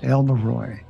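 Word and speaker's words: Elverhøj: But it's pronounced, I guess, for the sake of pronunciation, is Elverhoy. Correct? – That's correct. Pretend like the last letter, the J, is pronounced like a Y.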